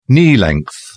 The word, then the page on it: knee-length: British English